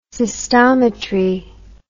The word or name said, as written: cystometry